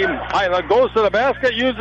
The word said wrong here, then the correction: Hilla: Hilla, Bennett Hilla is how you pronounce his name. Hilla.